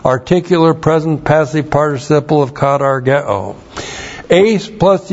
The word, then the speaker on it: katargeo: K-A-T-A-R-G-E long O. Katargeo.